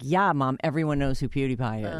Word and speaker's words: PewDiePie: It's PewDiePie, dummy.